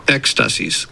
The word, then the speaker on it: Ecstasis: I'm not sure if I'm saying this word right, so let's hear the right way to say it by a Greek fellow. – Ecstasies.